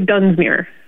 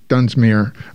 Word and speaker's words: Dunsmuir: Yeah, so it is the city of Dunsmuir. – Dunsmuir.